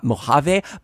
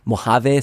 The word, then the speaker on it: Mojave: I just wanted to let you know that actually it's pronounced Mojave and not Mojave, but I'm actually just kind of too lazy to go back and edit every single instance of me saying Mojave, so this is what you get.